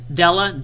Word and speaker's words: Della: Della, D-E-L-L-A.